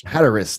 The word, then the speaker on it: Hatteras: off Cape Hatteras. I don't know if I pronounced that right. – Hatteras. – Thank you.